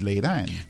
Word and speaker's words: Laadan: It's Laden. – Because it's spelled L-A-A-D-A-N.